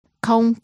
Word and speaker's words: không: Notice không is pronounced with no tone.